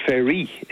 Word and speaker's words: Fery: Now, I initially heard the surname, I thought Ferry, okay, an English surname. His dad is French, it's Ferry. If you're his dad, I'm sure Arthur probably pronounces it Ferry, F-E-R-Y.